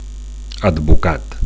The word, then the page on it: advocat: Catalan